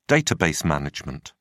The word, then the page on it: database management: British English